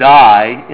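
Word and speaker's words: die (δεῖ): And because of that, Jesus, as he predicts his coming death, he uses a word that, oddly enough, is pronounced die in the Greek.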